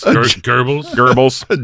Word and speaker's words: Goebbels: An anti-witch Gobbles. Something like that. Am I saying his name wrong? Gobbles? – Goebbels? – Jen Psaki of the anti-witch community.